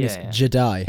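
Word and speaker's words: Je'daii: And of course, it was Tython where they first sort of rose to prominence, and the Jedi, but spelled a different way. So I don't actually know how you say it, but it's J-E-D apostrophe A-I-I. – I think Jedi.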